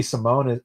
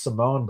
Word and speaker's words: Simon: Just like Ricky Simon, it's Simone.